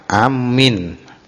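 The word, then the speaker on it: amin (آمّين): Jadi bacanya amin, hamzahnya pendek, mimnya ditasdid.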